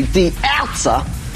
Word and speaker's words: die Ärzte: Die Ärzte, or pronounced properly, die Ärzte.